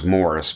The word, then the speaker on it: Maurice: Robin Gibb was harmony and backing vocals, and Maurice Gibb was harmony, backing vocals, and Maurice, or I've heard from their brothers that they actually called him, I think it's pronounced Morris, but it's...